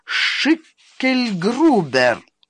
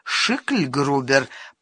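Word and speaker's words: Schicklgruber: «Ага, Шикельгрубер». «Скорее уж, Шикельгрубер», — поправил Бестужев. «Е» в данном случае не произносится. Я владею немецким.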